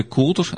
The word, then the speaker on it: kouter: Donc c'était euh, ce, ce coutre, donc euh, qu'on décrit euh, K-O-U-T-E-R, coutre, qui peut être prononcé coutre, enfin un coutre un peu plus ouvert, qui a peut-être donné quatre, hein, voilà.